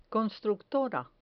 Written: constructora

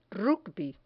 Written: rugby

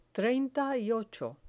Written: treinta y ocho

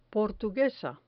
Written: portuguesa

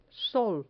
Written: sol

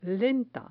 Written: lenta

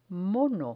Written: mono

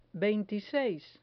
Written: veintiseis

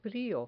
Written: frio

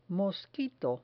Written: mosquito

